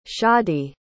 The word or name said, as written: shadi